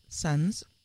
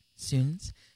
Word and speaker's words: Suuns: We just heard Suns, or Soons. It's spelled S-U-U-N-S.